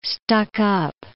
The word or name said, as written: stuck-up